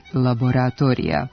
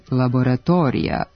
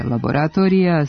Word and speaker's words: laboratorija: Pošto akcenatska norma ne dopušta silazne akcente van prvog sloga u reči, pogrešno je izgovarati laboratorija sa dugosilaznim akcentom na četvrtom slogu. Jedino ispravno bit će laboratorija sa kratkouzlaznim akcentom na slogu ispred i neakcentovanom dužinom na mestu na kome je bio preneti dugosilazni akcenat.